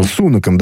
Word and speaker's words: Сунаком: Во время встречи он это заявление сделал премьером британским, господином Сунаком, или Сунаком, я все время забываю, где Сунаком. – Там... Сунаком, да, где ударение ставится, вот, спасибо.